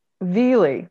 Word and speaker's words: Vigli: Then Mr. Mara, you're gonna have to help me with your name, Mara Vigli? – That's Mara Vili, silent G, Your Honor. – Vili, that's what I should have gone for, but at any rate, did not.